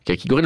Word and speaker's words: kakigori: Prononciation vraiment kakigori.